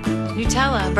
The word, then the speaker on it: Nutella: Sharon says it's pronounced Nutella, N-E-W-T-E-L-L-A. Everyone agrees on the end. Nobody agrees on the first syllable. Nutella? Nutella? Must be pronounced with the Italian oo sound for you. Nutella, N-O-O-double-O, is how one listener says you need to pronounce it. – Nutella, breakfast never tasted this good.